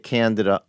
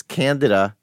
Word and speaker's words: Candida: It's Candida, not Candida, as you like to point out, sort of like that whole Kamala Harris thing.